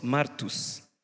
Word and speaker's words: martus: Mais le mot original pour témoin dans la Bible, dans le grec, c'est un mot qui se prononce martus. Martus, OK? Martus, M-A-R-T-U-S.